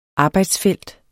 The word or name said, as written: arbejdsfelt